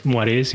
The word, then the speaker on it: moiré: Eh, y si se pronuncia Marués, Muarés, es de francés, es un verbo que traduce tornasolar.